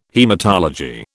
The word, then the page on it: haematology: British English